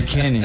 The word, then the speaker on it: Kane: Da Inglaterra é Kane. – Não quem? – Cane, não, viu? – Aí, dizendo que é Kane, você tá dizendo Cane. – É Kane. – É Kane. É Heineken, né, Kane.